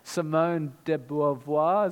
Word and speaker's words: Simone de Beauvoir: He had lifelong companionship with Simone de Beauvoir, I can't say French words, Simone de Beauvoir, is that how you say it? Okay, cool.